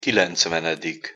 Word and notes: kilencvenedik: Hungarian

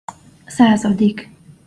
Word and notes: szazadik: Hungarian

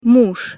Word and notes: muz: Russian

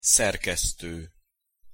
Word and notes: szerkeszto: Hungarian

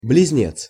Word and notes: bliznec: Russian

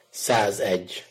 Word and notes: szazegy: Hungarian

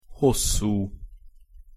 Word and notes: hosszu: Hungarian

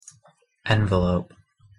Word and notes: envelope: noun

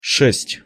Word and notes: sest-: Russian

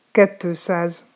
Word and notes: kettoszaz: Hungarian

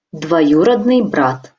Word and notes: dvojurodnyj brat: Russian

